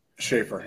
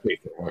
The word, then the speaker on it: Schaffer: Is it Schaffer or Schaffer? – Schaffer, yep. Schaffer.